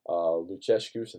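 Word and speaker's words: Lucescu: He also says, fun angle, as Kiev's coach, Luchescu, and that's how you pronounce it, ageless Romanian wizard, has been a Turkish national team coach and also Beşiktaş and Galatasaray.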